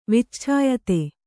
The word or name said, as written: ವಿಚ್ಛಾಯತೆ